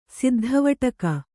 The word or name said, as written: ಸಿದ್ಧವಟಕ